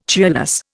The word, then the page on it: cheerless: Indian